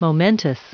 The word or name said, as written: momentous